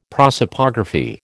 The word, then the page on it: prosopography: American English